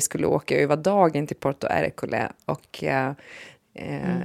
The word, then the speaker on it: Porto Ercole: Och vi var också besökte det här som du tipsade om, Sofie, Porto Ercole Uttalar man det så? – Ja, mm.